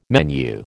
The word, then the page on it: menu: American English